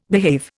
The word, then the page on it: behave: Indian